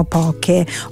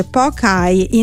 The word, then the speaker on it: poke: Non è una giornata istituzionale, è invece un appuntamento gastronomico internazionale dedicato alle origini e all'evoluzione del quello che noi chiamiamo poke o poke. Ma prima di tutto pronunciamolo bene, si dice pokai.